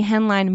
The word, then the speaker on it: Henline: Just, it's my handle is at Emily Henline Music. Mm. And my last name literally sounds like it's pronounced. It's Hen on a line. H-E-N-L-I-N-E. So y'all go check me out.